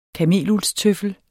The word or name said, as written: kameluldstøffel